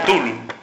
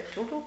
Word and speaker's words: Cthulhu: Bueno, otra cosita que también es curiosa es la pronunciación del nombre, ¿no? Yo se lo decía al principio, lo de Tulu, Cthulhu, Cthulhu. Se supone que el aparato fonológico humano no permite la pronunciación de ese nombre,